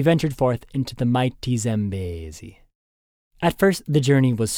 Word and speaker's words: Zambezi: Importantly, the river's name is pronounced Mighty Zambezi in your best Lion King voice. Linger on the bay and try to wrap your mouth around the whole syllable.